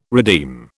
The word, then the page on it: redeem: British English